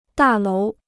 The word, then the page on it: 大楼: dà lóu